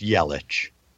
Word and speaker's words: Yelich: Although Ham, while we're throwing stones, in fairness, it's Yelich.